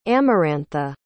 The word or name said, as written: amarantha